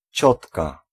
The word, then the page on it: tante: [tɑ̃t]; France